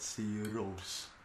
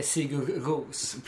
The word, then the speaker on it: Sigur Rós: Precis, men det står ändå på deras hemsida fonetiskt. Eller gjorde ja. Förut i alla fall. – Jo, hur man ska uttala det. – Men det finns ju inte en jävel som uttalar dem så. – Ja, gör. – Jo, men jag menar att alltså, när de är liksom utomlands och blir intervjuade så säger de alltid Sigur Rós.